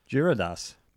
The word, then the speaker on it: Gyarados: Gyarados.